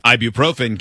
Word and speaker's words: ibuprofen: Ibuprofen.